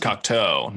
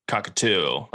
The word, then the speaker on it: Cocteau: It's actually pronounced Cocteau, not cockatoo, like the bird.